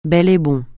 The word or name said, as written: bel et bon